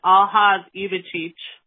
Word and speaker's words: Aljaž Ivačič: Anyways, his name is pronounced Aljaž Ivačič.